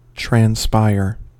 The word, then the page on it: transpire: American English